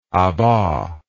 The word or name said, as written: abas